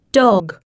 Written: dog

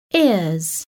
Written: ears

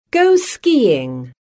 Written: go skiing

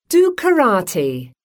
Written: do karate

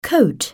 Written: coat

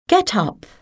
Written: get up

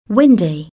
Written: windy

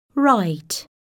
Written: write

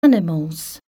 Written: animals

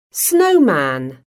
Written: snowman

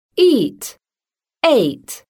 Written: eat-ate

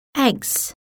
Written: eggs

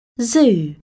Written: zoo

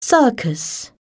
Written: circus

